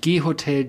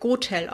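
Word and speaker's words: GHOTEL: Und zwar, es wird Gotel ausgesprochen. Und dieses Gotel kommt von dem Slogan Go to the Hotel, come to us. Und quasi kurz Gotel.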